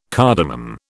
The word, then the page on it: cardamom: British English